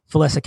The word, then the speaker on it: Felissa: Felicicon. I'm sorry if she eventually hears this, I'm sorry for mispronouncing her name. – Felissa kept pronouncing it properly, but yeah, it was more of like Felicicon.